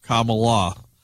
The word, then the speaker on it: Kamala: Ron writes this, Jeff, just an FYI, I read an article online from Harris California Days, and she said the correct pronunciation of her name is Kamala. Punctuation mark, comma, and then lock, Kamala.